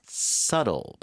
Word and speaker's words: subtle: Subtle. 발음 다시 한번요. – Subtle. – 비발음 없는 것처럼 subtle signals.